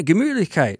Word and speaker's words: Gemütlichkeit: You can actually pronounce Gemütlichkeit. – Yeah, Gemütlichkeit. – See? Yeah. I put a sh in there. – You gotta have the umlaut, you know?